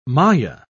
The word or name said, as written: maya